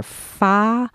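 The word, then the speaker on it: fa (發): Yeah, the 8 sounds like ba, and it rhymes with the Chinese character fa. That means strike it rich.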